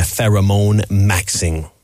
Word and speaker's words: pheromone maxing: tu connais le pheromone maxing? Je le prononce bien? – Tu le prononces très bien.